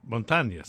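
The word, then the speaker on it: Montañez: How do you say it? Go ahead. – Montagnes. – Montagnes.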